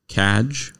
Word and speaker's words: cadge: Cadge. – Cadge. – Spell it, please. – C-A-D-G-E. – Cadge. – Cadge, like badge, cadge.